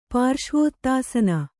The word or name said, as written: ಪಾರ್ಶ್ವೋತ್ತಾಸನ